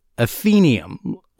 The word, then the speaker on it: Athenaeum: It's actually pronounced Athenaeum or Athenaeum.